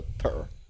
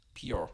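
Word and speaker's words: Petr: Well, it's spelled Peter, but it's P-E-T-R, so purr. – Pure.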